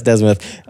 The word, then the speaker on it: Desmuth: D-E-S-M-U-T-H. – Okay. Oh, like that. Like D's mouth. All right. – Desmith. – Yes, Desmith.